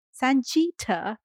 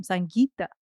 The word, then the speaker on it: Sangeeta: I said, if you can't be asked to pronounce my name, Sanjita, could I speak to you for a minute? I'm like, I'm not Sanjita. I'm Sangeeta. There's a difference.